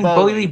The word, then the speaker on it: Boehly: How do you pronounce his surname? – Boehly.